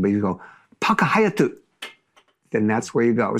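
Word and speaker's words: Paka Hayatu (パークハイアット): No, that hotel is called the Park Hyatt, or in Japanese, Paka Hayatu. That's how you say it.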